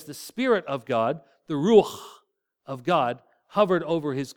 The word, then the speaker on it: ruach: You say that with me, ruach. And you have to, the if you haven't cleared your throat this morning, that's perfect. Ruach is the word. It's the way it's pronounced.